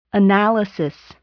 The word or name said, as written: analysis